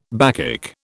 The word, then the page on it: Bacchic: British English